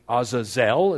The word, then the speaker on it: Azazel: Azazel is how it's pronounced, and meaning the goat of departure or the scapegoat.